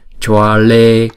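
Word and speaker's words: Jvale: Amukha Maha Mukha, Amukha Maha Mukha, Jwale Maha Jwale, Jwa Chữ J này chúng ta đọc là J. Chữ V này chúng ta đọc là W. Thấy không? Ghép lại là choa, choa. Choa lê, choa lê, choa lê.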